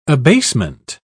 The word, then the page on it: abasement: British English